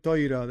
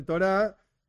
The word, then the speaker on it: Torah: Yo soy Ashkenazi, entonces estoy más acostumbrado a decir Toira. De vez en cuando me sale Torah porque estoy explicando esto o aquello, pero hay un video en el canal que habla de esto.